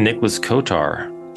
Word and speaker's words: Nicholas Kotar: I'm your guide and I am joined by a special guest this week, Deacon Nicholas Kotar. Deacon Nicholas, thank you for joining us. – It's a real pleasure, and thank you for pronouncing my last name correctly.